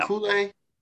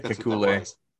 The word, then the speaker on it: Kekulé: Was that the Kekulé problem? Is that how you say it? Kekulé problem? – Yeah. – Kekulé? – Yeah. Yeah, Kekulé.